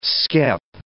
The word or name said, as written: skep